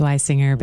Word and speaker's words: Wisinger: It's Weisinger, but it's okay. It's very rarely pronounced correctly.